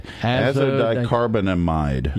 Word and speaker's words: azodicarbonamide: Azodicarbonamide.